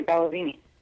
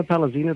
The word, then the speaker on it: Palazini: I'm sure you've run into that, like Palazinio or Palazzini or weird pronunciation of your last name for some reason. People cannot get it. It's funny how that works out. So just to make sure everyone knows, it's Dina Palazzini, P-A-L-A. Z-I-N-I.